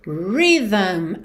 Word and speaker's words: rhythm: Atenção para você internalizar aí a pronúncia dessa palavra, porque a escrita dela é assim um pouco enigmática, né? R-H-Y-T-H-M. Então, a pronúncia dela, rhythm. Você, nesse TH, você vai pôr linguinha lá no céu da boca e vai fazer o som vocalizado. V, v, v.